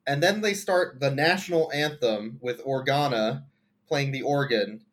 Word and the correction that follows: Organo: It was Organo. Organo. Get his name right.